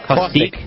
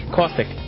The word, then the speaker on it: Caustiq: Yes, that was, Caustig. At least I think that's how it's pronounced. C-A-U-S-T-I-Q? – Caustic. Caustic. – Caustic, okay. – As in, like, acid.